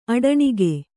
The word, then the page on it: ಅಟ್ಟಣಿಗೆ: aṭṭaṇige